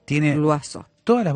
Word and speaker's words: Loiseau: Miel punto lo azú sería pronunciado correctamente, pero ustedes tienen que escribirlo. – Pronunciado es lo azú. Se escribe loiseau.